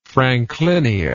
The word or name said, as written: franklinia